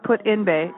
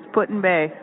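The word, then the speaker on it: Put-in-Bay: It's not Put in Bay. It's Put in Bay.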